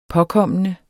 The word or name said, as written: påkommende